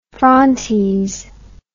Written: frontes

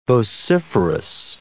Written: vociferous

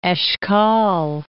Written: Eshkol

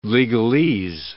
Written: legalese

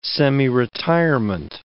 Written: semiretirement

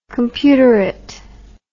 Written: computerite

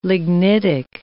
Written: lignitic

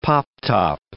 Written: pop-top

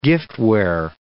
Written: giftware

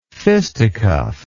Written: fisticuff

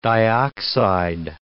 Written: dioxide

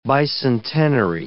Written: bicentenary

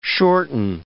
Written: shorten

